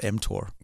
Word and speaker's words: mTOR: Yeah, you're pronouncing it correctly. It's called mTOR.